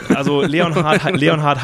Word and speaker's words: Leonhardt: Und die würden im Englischen einen Leonhard ohne H in der Mitte Lennart aussprechen. Wir wollten nicht Lennart Korn sein. Und äh, deswegen haben wir, zwingen wir den Engländer quasi Leon Hart zu sagen, auch wenn das Hart eigentlich E-A-R-T geschrieben wird.